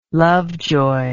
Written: lovejoy

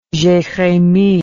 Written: jeremie

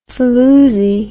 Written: faloosie

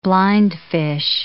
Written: blindfish

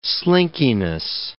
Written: slinkiness

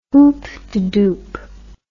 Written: hoopdedoop

